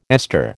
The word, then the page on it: ester: American English